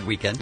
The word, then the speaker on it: Weeknd: It's actually just said Weeknd.